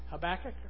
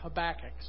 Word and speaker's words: Habakkuk: The Hebrew generally puts, unless there are other rules of grammar that come in, the Hebrew generally puts the emphasis on the second to last syllable, making it Habakkuk.